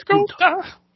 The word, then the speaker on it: Gunther: I should say it right. – It's Gunther.